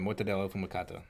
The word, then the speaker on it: mortadella affumicata: That's how I would say it. Mortadella fumicato.